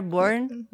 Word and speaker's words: born: When they are born. – Nobody's born. – Yeah, exactly. Every time that I say it out loud, it's like burned. No, it's not burned. When they are born.